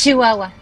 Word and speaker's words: Chihuahua: Que justo también desde Chihuahua, nunca lo sé pronunciar nada, pero. – Es Chihuahua.